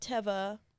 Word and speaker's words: Teva: Because the people at Teva responded to an Instagram post and they said it's pronounced Teva because it's the Hebrew word for nature.